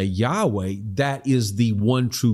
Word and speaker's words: Yahweh: or probably more accurately in Hebrew was pronounced Yahweh.